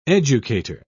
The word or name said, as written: educator